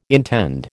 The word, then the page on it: intend: American English